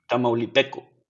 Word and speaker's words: tamaulipeco: Is it called Tamaulipeño or am I saying that wrong? – Tamaulipeco. – Yeah, I thought so. Okay. Tamaulipeco. Cool.